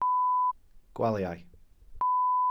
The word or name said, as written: gwaliau